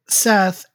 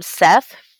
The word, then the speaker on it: Set: They either call him Seth for in Greek, or Set, or I can't pronounce that. I know it says it, but there's either Set or Seth, depending on where you're reading.